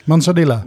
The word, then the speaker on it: Manzanilla: Dat, Moscatel, um, Oloroso, Manzilla, zeg ik dat goed? – Manzanilla. – Manzanilla, ja, ik, heb, alleen verstand van whisky en niet van sherry.